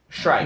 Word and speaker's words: Streich: Strike. – Never mind. I don't want a manager that I can't pronounce his name. – It's Strike. Streich. Strike.